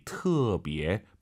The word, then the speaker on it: 特别: 무지무지 터비에 발음하실 때 터 아니고 트로 시작해야 돼요. 터비에.